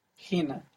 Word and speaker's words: hina: Hina. – Hina. So by default, most of the time, the accent's going to be on the penultimate syllable, the second last syllable, like in hina.